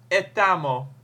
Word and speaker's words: estamos: Estamos wordt bijvoorbeeld vaak uitgesproken als etamo.